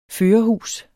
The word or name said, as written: førerhus